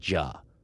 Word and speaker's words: Juh: The most classic way it's written is J-U-H. You would pronounce it Ja. Another book will write it as W-H-O-A.